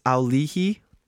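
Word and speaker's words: Auli'i: I knew Ali Cravalho. – I looked this up. – Is that how you pronounce that? – It's Aulihi. Oh. Cravalho.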